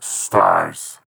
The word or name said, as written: Stars